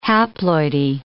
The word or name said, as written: haploidy